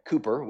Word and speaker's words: Cowper: one was named William Cowper his last name is spelled C O W P E R. Doesn't sound like how you'd say it, but Cooper, William Cowper, he's a famous poet, uh, but also a famous hymn writer um, as well.